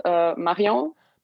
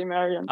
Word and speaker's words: Marion: I mean, in French, it's just Marion. – Marion, okay. Okay, well. – Yeah. With the English accent, but you can say Marion.